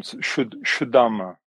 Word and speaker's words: Zuddharma: So it's pronounced Shuddharma. – Shuddharma. – The Z is a sh.